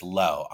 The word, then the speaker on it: Lo: So, yeah, I get a lot of mispronunciations of it, but it's easy breezy, just Low. I'll go by Low, which is short for Lorenzo, which is my first name.